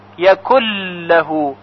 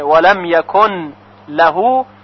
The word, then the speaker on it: يكن له: یعنی نون ساکن یکن در لام لهو ادغام میشه، بنابراین فقط یک لام مشدد شنیده میشه، یکن لهو یکل لهو، یعنی نون دیگه تلفظ نمیشه. ولم یکل لهو، البته اگر کسی ادغام نکنه و بخونه ولم یکن لهو، نماز او اشکالی نداره.